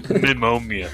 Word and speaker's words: pneumonia: Your mic gain increased and you just went pneumonia. – Pneumonia. And that's pneumonia. – What's wrong with you? – I know you're not supposed to pronounce the P, but fuck it, I'm just fucking mutual.